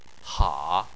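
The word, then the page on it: ha: tone 3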